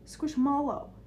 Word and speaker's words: Squishmallow: It's like pillow versus pillow. – Squishmallow. – Squishmallow. – Wait, what? – Like mallow. – Like marshmallow. Squishmallow.